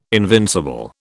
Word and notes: invincible: British English

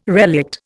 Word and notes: relict: Indian

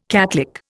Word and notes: catlick: Indian

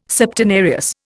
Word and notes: Septenarius: Indian

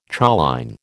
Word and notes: choline: American English

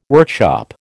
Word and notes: workshop: American English

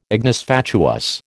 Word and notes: ignis fatuus: American English

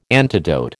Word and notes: antidote: American English

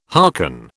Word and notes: harken: British English

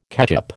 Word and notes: ketchup: American English

